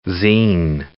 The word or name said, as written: zine